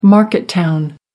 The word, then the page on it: market town: American English